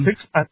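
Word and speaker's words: Sixt: Like six, I think it's pronounced six, but anyway,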